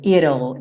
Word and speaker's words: it'll: Como é que eu pronuncio esse it will quando está contraído, né? Esse it'll, é meio que um it'll.